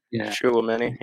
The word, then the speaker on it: Tchouaméni: I can never get that right. Tchouaméni or, Tchouaméni, yeah.